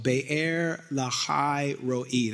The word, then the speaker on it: Be'er Lahai Roi: and Isaac dwelt in this place called Be'er Lahai Roi. That is the proper pronunciation.